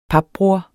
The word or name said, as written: papbror